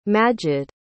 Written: majid

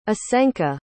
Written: asenka

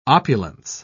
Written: opulence

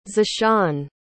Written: zeshawn

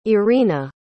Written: irina

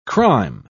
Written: crime

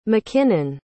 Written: mckinnon

